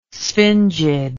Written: sphingid

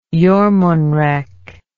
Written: jormunrek

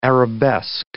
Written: arabesque

